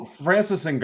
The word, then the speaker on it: Francis Ngannou: Oh, Francis Ngannou.